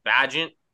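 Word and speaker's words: Bagent: Badgett.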